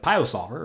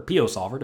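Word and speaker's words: PioSOLVER: A lot of people have only heard of PIO Solver, or PO Solver, depending on how you say it, because that is the one that has been around the longest.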